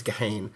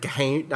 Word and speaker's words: Gehirn: Uh, yeah, G E H I R N, and the, the pronunciation I've written down here is Gehane. Um, maybe a little R in there at the end. Gahane.